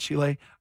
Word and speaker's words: Chile: If Chile and his Chilean fans were really so important, are you supposed to say it, Chile? I feel like I'm being pretentious saying it that way. – I think it's fine. – How do you say the country? Okay, Chile. – I hate people that put a little extra hot sauce on it and they don't need to. – No, you're pronouncing it right, actually.